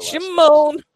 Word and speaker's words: Chamone: I'm terrible with Chamone. You gotta do it like Michael Jackson.